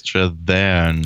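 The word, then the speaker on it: Strathairn: Äh, ansonsten haben wir natürlich noch David Strathern. Strathern. Strathern. Richtig.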